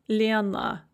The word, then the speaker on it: Лена: With мила, Лена, and Нина, we have an O or an A that is after the stress, and so they're all pronounced kind of an E sound. Мила, Лена, Нина.